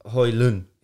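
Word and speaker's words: Højlund: Hoyland. He said he doesn't. Yeah, it's no a silent D.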